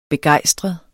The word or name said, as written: begejstret